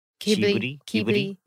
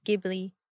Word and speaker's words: Ghibli: From like the… Studio… Ghibli. – Yes. – Ghibli.